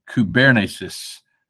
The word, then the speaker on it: kubernesis: I pronounced that word wrong. It's actually kubernesis.